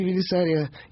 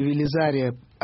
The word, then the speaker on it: Велизария: В биографии Велесария или Велизария одинаково можно произносить.